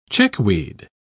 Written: chickweed

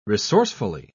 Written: resourcefully

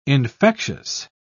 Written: infectious

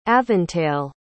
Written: aventail

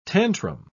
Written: tantrum

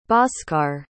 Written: bhasker

